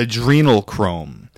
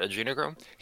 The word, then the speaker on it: adrenochrome: And he's definitely no expert in like biology and that sort of thing, but I find it and you know, he's saying it wrong. It's adrenochrome, really? I think, is what we say now. I'm pretty sure that's right.